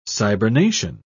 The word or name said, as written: cybernation